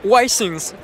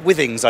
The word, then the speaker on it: Withings: And we caught up with Withings, although we pronounced the name a little bit wrongly. – It's Withings, French company based in Paris. – Okay, you see, we call it Withings over here. – Yeah, there's no official rule, so it's as you want.